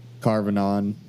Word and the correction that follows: Carnarvon: Carnarvon. – Carvavon. Okay.